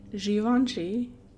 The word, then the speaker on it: Givenchy: Is it Givenchy? – Yeah.